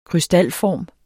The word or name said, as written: krystalform